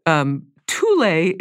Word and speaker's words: Thule: And then they're competing with the Thule is the correct pronunciation of this.